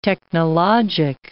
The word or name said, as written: technologic